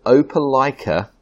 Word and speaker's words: Opelika: I'm in a place called Opelika, Alabama, which is, when the locals say it, it sounds something like, I hope you like us, or hope Opelika.